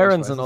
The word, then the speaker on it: Aaronson: Und zwar für Aaronson, oder spricht man den Aaronson? Oder Aaronson aus USA.